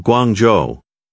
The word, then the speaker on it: 广州: Now you're an American man doing business in the city of Guangzhou. The city's name is pronounced with falling, rising, and high-level tones.